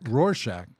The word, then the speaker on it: Rorschach: Also known as the Rorschach. – Rorschach.